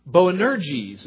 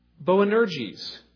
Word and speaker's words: Boanerges: Boanerges is how you say it. Boanerges. And that's an Aramaic term. It's not a Greek term.